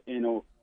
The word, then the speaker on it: Nolan: Sometimes I thought I should have spelled the Noland, which means no land. You know, and a lot of when I was growing up, that's how they pronounced Nolan, as no land.